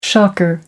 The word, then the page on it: shocker: American English